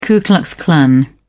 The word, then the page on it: ku klux klan: British English